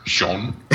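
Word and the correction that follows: Sjón: Yes. – Or something along those lines. – Shun. – Shun, yes. It's a shun.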